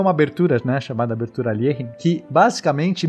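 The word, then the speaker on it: Alekhine: falar o nome dele corretamente, é Alierin, né, a pronúncia russa.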